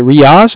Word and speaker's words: Riyaaz: What's a good, is it Riaz? Am I saying that right? – R-I-Y-A-A-Z.